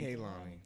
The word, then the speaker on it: Kehlani: Kehlani. – That is my little baby. I am a fan of hers. – Hey, Kehlani. – Hi, Kehlani. She never smoked cigarettes. She still smoked weed. Don't disrespect Kehlani on the show.